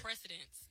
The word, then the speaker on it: precedence: It's not, I have a, what do you call it, a president, presidential case? – Yeah. – Is that, did I pronounce it right? – Precedence, yes.